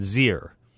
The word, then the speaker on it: xyr: So, for example, XE slash XYR is commonly pronounced Z and Zir, as in, I asked Zir to come to the movies, and Z said yes.